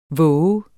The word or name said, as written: vov